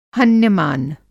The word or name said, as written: हन्यमान